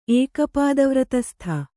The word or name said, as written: ಏಕಪಾದವ್ರತಸ್ಥ